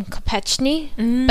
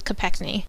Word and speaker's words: Kopechny: I believe they actually used to pronounce their last name Kopechny, but I think as they came here, they just went with Kopechny.